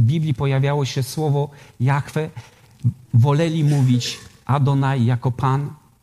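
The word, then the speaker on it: Jahwe: My wiemy, że to imię po hebrajsku brzmi Jachwę, Jehowa. Różnie, różnie, różnie to jest, jest, jest odczytywane.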